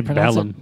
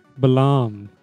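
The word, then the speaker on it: Balamb: Is that how you spell it? – I say Balam. – I say Balam, too. – I say Balam. – Or really? – Balam. Mm-hmm. – Like a quiet B.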